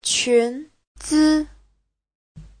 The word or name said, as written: qun2zi5